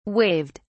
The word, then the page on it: waived: British English